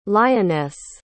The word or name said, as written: lyonesse